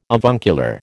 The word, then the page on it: avuncular: American English